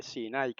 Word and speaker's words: Sinai: Fíjense que Sinaí no lleva acento en la I, como todo el mundo lee, ¿verdad? Gracias al griego. Es Sinai que se dice.